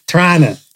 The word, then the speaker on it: Toronto: Tirana. – Yeah. – Like, apparently you guys don't pronounce the T. – No, the T's. – That's so stupid. – The T's silent. It's like the G in lasagna.